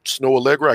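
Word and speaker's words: Snoh Aalegra: Snow Allegra. I can't spell her name, but look it up. It's not S. Yeah, S-N-O-H. Not Snow. Pronounce Snow, but S-N-O-H Allegra.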